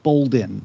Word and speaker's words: Boldin: Is it terrible if I mention that my last name is pronounced Boldin without the R?